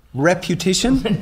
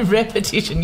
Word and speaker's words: repetition: Reputation? – No, repetition. You say it wrong all of the time.